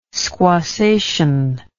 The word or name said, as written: squassation